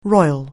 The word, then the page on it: royal: anglais